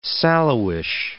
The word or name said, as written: sallowish